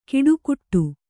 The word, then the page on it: ಕಿಡುಕುಟ್ಟು: kiḍukuṭṭu